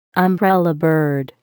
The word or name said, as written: umbrellabird